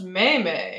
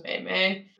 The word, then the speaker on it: meme: I think it's pronounced meme. – Oh, Lord. – Got into an argument with someone about that once. She was so convinced it was meme.